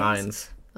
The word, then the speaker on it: Fiennes: Is it Fiennes? Ralph Fiennes? – Fiennes. – Oh, I did it. That's not how you spell it, though.